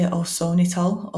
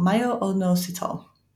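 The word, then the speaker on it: myo-inositol: I always want to say osonitol, onositol, myo-onositol.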